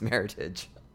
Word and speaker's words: Meritage: It's not a French word. It's actually an English word. It's a marriage of different blends, and it's Meritage.